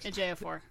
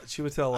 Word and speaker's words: Ejiofor: Yes, This movie, Talk to Me, starring Don Cheadle, and I'm going to learn how to pronounce this, Chiwetel Elijah. How do you say it? – Ejiofor. – Chiwetel Ejiofor.